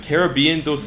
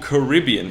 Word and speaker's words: Caribbean: So, 어, well, 둘다 써요. 사실 Caribbean도 쓰고 Caribbean 이렇게 발음 두 개 있습니다.